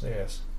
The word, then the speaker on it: dais: But it's pronounced dais.